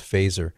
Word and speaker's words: Feser: Well, one person who has critiqued it, in print especially, is Dr. Edward Fesser, F-E-S-E-R. It looks like Fesser, but it's pronounced Fesser.